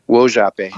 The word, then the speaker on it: Wojape: And it is, can you pronounce it for me? Is it Wojape? – Wojape. – I'm sorry, Wojape. Excellent.